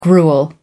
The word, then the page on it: gruel: American English